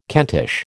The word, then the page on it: Kentish: American English